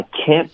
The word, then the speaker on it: can't: 好，我们要学的是 I can't believe how lucky I am。然后我们今天顺便聊一下 can't 的发音，我们要聊的是这个美式的发音，英式发音是 can't，然后这个 can't 的这个音呢，有的学生喜欢用一个 on 的音， on 不太对，可以用一个前鼻音 can't。